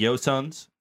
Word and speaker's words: Joseon: You said the Joseons? – Yeah, Joseon.